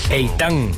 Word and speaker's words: Ethan: Es que no lo pronuncian así, caballero. – A ver, ¿cómo está? – Ethan. Recuérdese que el hijo de José Ramón Fernández, que narra en ESPN, su nombre es Ethan.